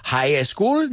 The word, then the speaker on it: high school: No high school, porque si pausas el E se mete. High school, no. High school, high school.